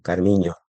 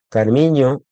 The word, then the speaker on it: Carminho: Carminho, que la ñ en portugués se escribe NH, Carminho. Carminho, en entrevistas en español, que habla muy buen español, ella menciona la importancia que tiene en el fado la letra.